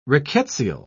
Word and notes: rickettsial: British English

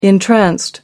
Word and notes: entranced: American English

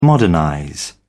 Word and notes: modernize: British English

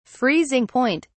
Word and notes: freezing point: British English

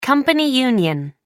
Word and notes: company union: American English

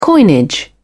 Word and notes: coinage: British English

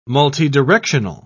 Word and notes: multidirectional: British English

